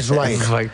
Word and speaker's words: Zweig: Por el camino bien, ahora hay que decir Zweig. – Zweig. – Zweig. Esteban Zweig. – Es así como se pronuncia en alemán.